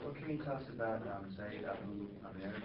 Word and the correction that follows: Ziyad Abu Amr: the guy who wrote about the... – Ziyad Abu Amr. – My pronunciation.